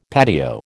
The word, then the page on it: patio: American English